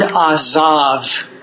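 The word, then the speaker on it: azav (עזב): The word forsaken here is the word azav. We would spell it A-Z-A-V-H.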